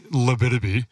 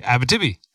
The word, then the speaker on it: Abitibi: Like you Americans would say Labitibi. – Yep. – Like the junior stage race that everyone goes to in Quebec, but Canadians say Abitibi. – Uh-huh. – Yeah, the Abitibi Canyon Dam, which was on our route.